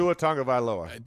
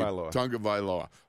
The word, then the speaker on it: Tagovailoa: Tua Tagovailoa. – Thank you. Tagovailoa.